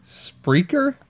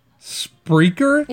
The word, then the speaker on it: Spreaker: Sprekaker. – Sprekaker. Spreaker? – That's what we're on. – Spreaker? – Yeah.